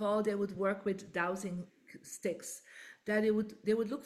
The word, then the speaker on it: dowsing: we pronounce it dowsing. – Oh, Yeah. – douse. That's why I'm like, douse, douse. Okay, I understand the word douse, but what does it mean in terms of feng shui and manifesting?